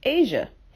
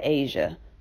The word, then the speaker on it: Asiha: But her name is spelled uniquely. It's spelled A-S-I-H-A.